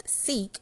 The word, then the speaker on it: SEAC: So, SEAC, pronounced SEEK, is Self-Embrace Academy Clubs.